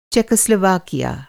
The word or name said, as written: चेकोस्लोवाकिया